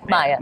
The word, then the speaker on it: Maya: How do you go from, because in the video game world, you also played the sassy siren Maya. Is that how you pronounce it? Is it Maya or Maya? – Maya.